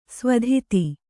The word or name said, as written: ಸ್ವಧಿತಿ